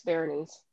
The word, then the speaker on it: Berenice: So it's called Berenice, and I guess I'm pronouncing that correctly. I've seen like four different ways to pronounce it online. But it looks like Bernice, but it's not. It's Berenice.